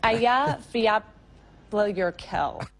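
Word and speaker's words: Eyjafjallajökull: So this is how you pronounce this this tongue twister of a volcano. – Eya, Flaya, Yakur, Vol. – It's Aya Fiablyurkel. – Aya Volik. – That's it? – Aya Fetla Yakuk. Am I pronouncing that correctly? – Nearly.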